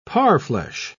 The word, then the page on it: parfleche: British English